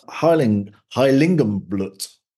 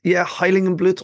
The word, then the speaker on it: Heiligenblut: You're going to tell me all about Heiligenblut. – Yeah, I think, Heiligenblut or Heiligenblut. I think you pronounced it pretty spot on there.